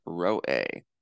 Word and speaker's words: roeh: A seer is translated from the Hebrew word roeh, and it rhymes with the word for lion, which is aryeh.